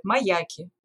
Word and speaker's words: маяки: Смотри, можно сказать про то, что гидрографы наши российские ставят неправильное ударение в слове «маяк». Они говорят не «маяки», а «маяки» и ты всегда можешь понять, что человек из гидрографической службы или как-то связан с флотом, если ты слышишь такое ударение.